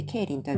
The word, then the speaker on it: 桂林: ケイリンというとね、皆さん聞いたことがあるでしょうかあの、日本語の、日本語で読みすると、カツラ、木片のカツラですね。これに林と書いてケイリンと読みます。